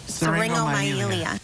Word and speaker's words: syringomyelia: Syringomyelia. – Syringomyelia.